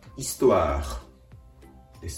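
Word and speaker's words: histoire: 歴史はフランス語で、イストワーです。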